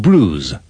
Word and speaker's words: bruise: Editorial Planeta de Agostini presenta Curso de Inglés Second Level Unit 52 Speaking At the surgery In these sentences, pay attention to the pronunciation of the UI in bruise. – When we went riding in the New Forest, I fell and bruised my arm.